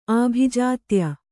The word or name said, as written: ಆಭಿಜಾತ್ಯ